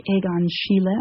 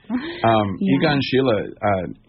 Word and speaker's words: Egon Schiele: That was, I've been doing a lot of writing from looking at these paintings by Egon Schiele, I think is how you would pronounce it in German.